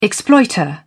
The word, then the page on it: exploiter: British English